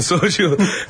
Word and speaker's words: soju: pero el soju.